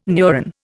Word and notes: neuron: Indian

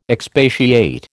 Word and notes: expatiate: American English

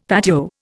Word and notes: patio: Indian

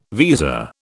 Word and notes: visa: British English

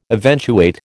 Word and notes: eventuate: American English

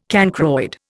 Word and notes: cancroid: Indian

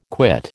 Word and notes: quit: American English

